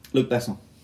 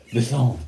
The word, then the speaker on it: Besson: I don't know if I'm saying it right. I'm just putting a French accent on. – Anyway, Luc Besson had just funded a movie Oldman... Yeah. – Besson. Luc Besson.